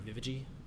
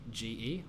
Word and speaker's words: Wewege: My personal Instagram, follow me at Andrew Wewege. It's spelt Andrew _ Wewege.